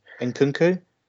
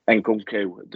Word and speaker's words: Nkunku: Nkunku? – Nkunku, there's rumours of us going after him.